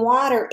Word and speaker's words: water: Water has that ER sound at the end. We can't hear the E. We just hear the R.